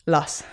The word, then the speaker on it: Luss: Luss.